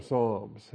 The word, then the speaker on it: psalms: And even the English word psalms, the P is silent, by the way, even if a particular prominent politician can't get that right. Psalms with a silent P, or psalmos, if you want to do the ps sound for your Greek psi, that's fine.